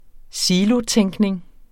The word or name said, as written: silotænkning